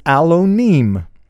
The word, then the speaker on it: Alonim: My last name is Alonim. It is spelled A-L-O-N-I-M, if we transliterate it, bring it from the Hebrew characters into the English alphabet.